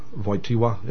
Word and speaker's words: Wojtyła: They put in of the eclipse of the sun because they say that this pope, Karol Wojtyła, Wojtyła is how you say it in English, Wojtyła, they say that he...